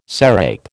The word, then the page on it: sarape: American English